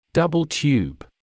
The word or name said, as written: double tube